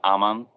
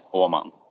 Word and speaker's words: Åhman: Like in English, everyone says Åhman, but in Swedish, it's Åhman, but yeah. It doesn't really matter. I don't care.